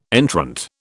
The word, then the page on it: entrant: British English